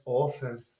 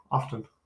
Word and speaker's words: often: You're not supposed to pronounce the T. It's often. Often. Often.